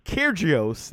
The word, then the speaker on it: Kyrgios: Djokovic beat Kyrgios. That's how you say it.